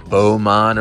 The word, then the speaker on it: Beau Monde: I just shot some video of, how do you say it? – Beaumont Originals. – I'm still trying to get the word, which is French for beautiful world. – Beautiful world. Beaumont is beautiful world.